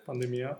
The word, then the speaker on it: pandemia: la diffusione di un virus adesso mi dirai come si pronuncia eh, l'accento qui che io lo sbaglio sempre. – Pandemia.